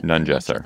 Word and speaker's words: Nungesser: and Emily will pronounce his name for us, Nungesser? – I've been saying Nungesser, but Nungesser. – That's right.